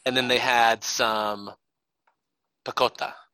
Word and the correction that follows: pakora: Is that how you say it? – Pakora.